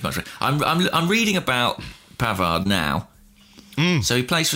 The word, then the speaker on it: Pavard: Pavard.